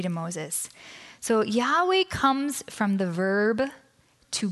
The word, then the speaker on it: Yahweh: But there are many reasons why biblical scholars are confident that it should, in fact, be pronounced Yahweh.